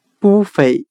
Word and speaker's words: buffet: 我们用谐音法来记忆这个单词 buffet，它的发音很像汉语的“ 不菲”。